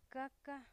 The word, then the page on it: caca: 'kaka